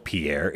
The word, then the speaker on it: Pierre: Wait, it's not Pierre. No, it's Pierre. I mean, it is. Oops. It's spelled Pierre. It's. I mean, if you ask Ashley or anybody over there, it's Pierre. But it's uh, we we Pierre. freedom fries and we pronounce mm. it Pierre.